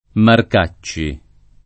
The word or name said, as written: Marcacci